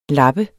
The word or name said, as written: lap